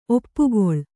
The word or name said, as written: ಒಪ್ಪುಗೊಳು